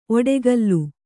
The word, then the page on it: ಒಡಕಲು: oḍakalu